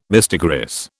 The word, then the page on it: mistigris: British English